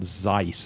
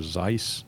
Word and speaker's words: Xyce: It's X-Y-C-E. – Zeiss. – And I wouldn't have been able to pronounce that other than the first thing on its website says, Rhymes with Spice.